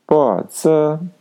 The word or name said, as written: bo2zi